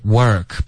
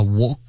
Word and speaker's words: work: Okay, work 别发 walk. OK, 这是想要模仿那个英式的发音可是你这样模仿的时候听起来会像 walk, walk,、嗯、所以 work, 最好是嘴唇放松。